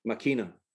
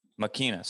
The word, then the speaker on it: Máquina: Máquina. – Máquina. So Máquina Matata. So the Spanish, you take, Spanish for the machine. – The machine. – The machine. – I like it. Máquina needs opportunities to go out and explore and hunt.